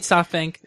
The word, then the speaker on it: SoftBank: And this week, it's all about SoftBlank. I mean SoftBank.